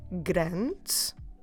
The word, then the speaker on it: grande: And in that case, if you want a big one, you should say uma água grande. Uma água grande. Como se escreve grande? G-R-A-N-D-E. Grande. Don't forget to drop the last E.